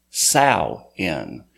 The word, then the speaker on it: Samhain: And there is a tradition, and I believe it was put in the newsletter, that it's a Celtic celebration, and it is spelled S-A-W-H-E-I-M, and it's actually pronounced Samhain.